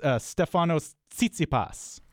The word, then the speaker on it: Stefanos Tsitsipas: But it's Stefanos Tsitsipas.